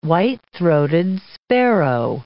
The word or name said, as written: white-throated sparrow